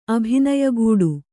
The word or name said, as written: ಅಭಿನಯಗೂಡು